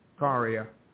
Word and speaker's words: Ikaria: So last but certainly not least, now, you, if you would, what is the proper way to pronounce Ikaria in Greece? – Ikaria. – Ikaria.